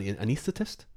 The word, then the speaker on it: anaesthetist: So he is an anaesthetist. – Yep, I said that right.